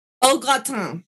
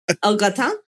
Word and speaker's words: au gratin: O-A-U. Au gratin is actually the French pronunciation. Au gratin.